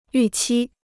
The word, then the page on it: 预期: yù qī